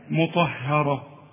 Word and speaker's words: مطهرة: الله، ما احنا قلنا يا راجل هذا، قالوا ايه هذا اله بتفخم هاني ليه، هنا بتقول لي مطهرة يا راجل، ها ها مطهرة، خلاص الراء مفخمة والطاء كمان مفخمة، ها، لكن اله هي المرققة، شوف مطهرة، ها.